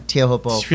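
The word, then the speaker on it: Teahupo'o: and I've changed my pronunciation. – You know, I think everyone has. It's really weird. – I'm just going Tiahupo. I heard Mickey Manabre say it a bunch of times on the beach.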